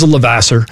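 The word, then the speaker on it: Levasseur: one being Raymond Luke Lavoisier. Am I saying that right? – He's from Maine. He just says Lavasser. – Lavasser. Okay. Good old Mainer.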